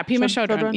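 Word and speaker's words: Pema Chödrön: Pema Chodron. – Oh yeah, Pema Chodron.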